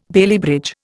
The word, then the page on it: Bailey bridge: Indian